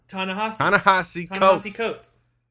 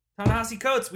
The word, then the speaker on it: Ta-Nehisi Coates: Ta-Nehisi Coates. Ta-Nehisi Coates.